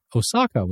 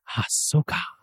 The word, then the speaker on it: Ahsoka: I was the grumpy old geek who tried to get you to say Ahsoka correctly before, but you didn't get it right. You keep saying Osaka, which is a city in Japan, when it should be Ahsoka.